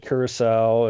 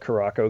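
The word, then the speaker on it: Curaçao: and Curacao, and or I call it Caraco because that's the way it's spelled.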